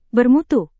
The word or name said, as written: bermutu